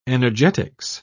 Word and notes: energetics: British English

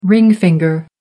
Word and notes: ring finger: American English

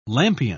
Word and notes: lampion: British English

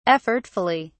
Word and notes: effortfully: British English

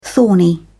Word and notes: thorny: British English

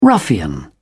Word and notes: ruffian: British English

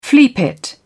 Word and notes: fleapit: British English

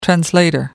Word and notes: translator: American English

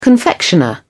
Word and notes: confectioner: British English

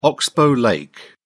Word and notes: oxbow lake: British English

